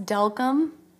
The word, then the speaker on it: Delcambre: Delcambre. – Delcambre. Mm-hmm. Brie or Bray? – Delcambre. – Brie, okay. – It's actually pronounced Delcambre.